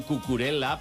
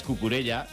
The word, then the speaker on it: Cucurella: Que por cierto, en su anterior equipo, en el Chelsea, le cantaban Cucurella porque no lo pronunciaban bien. Y hablaban de la paella. – Como paella, eso. – La paella para Cucurella. Pues nada, a partir de ahora ya decimos bien su apellido, que es Cucurella.